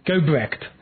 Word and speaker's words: Gobrecht: Welcome back to Blue Republican Radio for the Liberty Curious, and I'm speaking to Cindy Biondi Gobrecht. No, I keep saying that wrong, don't I, Cindy? Gobrecht. It's the emphasis. I got to get that right.